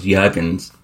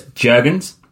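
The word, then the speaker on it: Jürgens: I actually thought it was Jurgens because the J is silent in German, but it's Jurgens.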